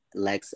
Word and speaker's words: Lex: It's spelled exactly how you hear it. Lex, L-E-X, lyrics, L-Y-R-I-C-S.